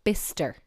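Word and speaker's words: Bicester: And what about that place that looks like it's Bychester? No, it's "bister." And that very interesting place in Cambridgeshire, God Manchester.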